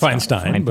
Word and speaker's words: Feinstein: I never know how to pronounce it. it's Feinstein. It's Feinstein thank you, thank you, Feinstein. I, I always pronounce it wrong.